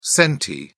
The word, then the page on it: centi: British English